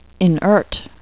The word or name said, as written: inert